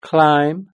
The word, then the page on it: climb: British English